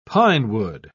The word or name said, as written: pinewood